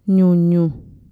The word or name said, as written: ñuñu